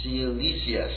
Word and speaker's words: Cilicia: And when he had read it, he asked from what province he was, and he learned that he was from Sicilia. Did I pronounce that right? Cilicia, excuse me.